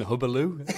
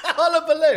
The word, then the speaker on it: hullabaloo: That was Matthew mispronouncing Hubbaloo. – Hullabaloo.